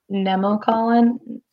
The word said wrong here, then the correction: Nemacolin: Yeah, Nemacolin Estate. – Woodlanders. – Nemacolin.